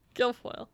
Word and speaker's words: Guilfoyle: I don't know how to say her name. Guilfoyle. – Guilfoyle. – Guilfoyle. Did I get it? – Yeah.